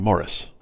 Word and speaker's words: Maurice: but I did really like Amazing Maurice, so maybe I should give them a shot. – Mom said it's The Amazing Morris. – I know that's the English pronunciation, but people don't know what that means if you say it.